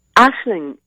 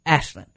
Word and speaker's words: Aislinn: Aisling. It's a very Irish name. It's kind of a standard Irish name. But yeah, I know it doesn't translate well, does it? – No, it doesn't. And I knew basically what it was, but I didn't want to... Okay, let's say it again. – Aisling. – Because it's spelled A-I-S-L-I-N-N, which for people who are not familiar with, well, there are so many Irish names where they sound not at all like what they look like.